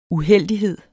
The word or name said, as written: uheldighed